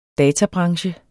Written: databranche

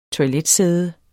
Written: toiletsæde

